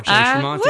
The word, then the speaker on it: Jay Tramonte: Am I saying that right? – You are, Jay Tramonte.